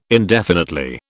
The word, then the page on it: indefinitely: British English